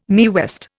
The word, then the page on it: Mae West: Indian